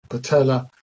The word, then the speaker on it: patella: Similarly with patella, patella has two L's, and so the E is pronounced E.